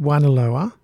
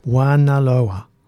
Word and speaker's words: Juanaloa: Wanaloa. Now, I'm going to spell that for people because it's a bit confusing, isn't it? Wan, as in Juan, Juanita, J-U-A-N-A-L-O-A, Wanaloa.